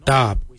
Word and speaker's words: dhaab: Today we will look at, for knife we say darb. Darb is spelled D-H-A-A-B.